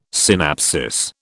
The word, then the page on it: synapsis: British English